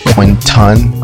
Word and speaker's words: Quinton: Most people call me Q Ward, but it's really just because they don't feel like saying Quinton. Like that special pronunciation of that O gives people a lot of pause, and they end up calling me Quentin. And that's not actually my name, so we just go with Q, but I won't get into that.